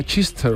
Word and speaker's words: Chichester: Nos hace gracia el nombre de Chichester, pero el nombre ya es de coña. – Esto, como el... Eso sería, se pronunciará Chichester o algo así, ¿no? – Chichester, claro.